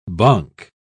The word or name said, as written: bonk